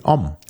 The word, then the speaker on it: Homme: Nee, Om. – Om. – Mann. Om wie Om. Mann. Om. O-M einfach nur ausgesprochen.